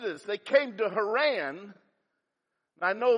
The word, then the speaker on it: Haran: The names, Haran was how you say it, is his son.